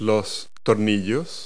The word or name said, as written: screws